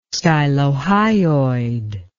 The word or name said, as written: stylohyoid